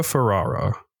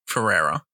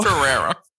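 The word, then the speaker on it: Ferrera: America Ferrara. – Ferrara. – Fuck. What was it again? Ferrara? – Ferrara.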